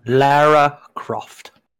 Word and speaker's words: Lara Croft: Lara Croft. – And Dustin. – Dang it. He said it right. I was so excited to call someone out for saying it wrong. It is Lara Croft.